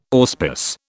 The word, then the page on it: auspice: British English